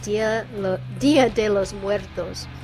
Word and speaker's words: Dia de los Muertos: Very, very cool band called Twin Tones with their song Dia de los Muertos.